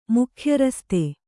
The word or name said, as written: ಮುಖ್ಯರಸ್ತೆ